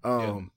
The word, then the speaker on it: Nakia: But Let's go to Nakia. Am I saying that right? Lupita's character. – Nakia.